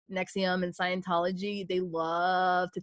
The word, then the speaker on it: NXIVM: So basically there was a cult called NXIVM and it's spelled NXIVM, but not to be confused with the acid reflux medication NXIVM. It's pronounced the same way, but spelled different.